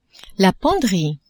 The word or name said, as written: penderie